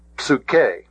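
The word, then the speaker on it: Psuche (ψυχή): Psuche, psuche. I keep getting the accent on the wrong syllable. Psuche.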